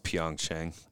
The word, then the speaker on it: Pyeongchang: And then they captured gold in Pyeongchang. I hope I say that right. – I think it's Pyeongchang.